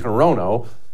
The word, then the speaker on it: Hirono: Horino. Her name's Maisie Hirono. But don't feel bad for her getting her name mispronounced.